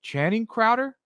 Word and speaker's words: Channing Crowder: So it's, was it Channing Crowder? Is that his, or? – Yeah, what's yes, I think that's how it's pronounced, yes.